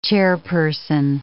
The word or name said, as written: chairperson